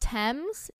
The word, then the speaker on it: Tems: I want to say it's pronounced Thames, T-E-M-S.